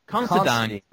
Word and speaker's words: Considine: Constantine, isn't it? – Considine. That's it.